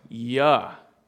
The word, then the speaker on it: Jehoshaphat: There's a king whose name is Jehoshaphat in the Old Testament. And remember, Hebrew cannot really pronounce the J sound, so his name would have been Yahoshaphat, right?